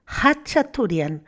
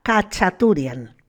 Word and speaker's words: Khachaturian: Los armenios lo pronuncian Hachaturian sin tener en cuenta la K. Nosotros decimos la K, Kachaturian.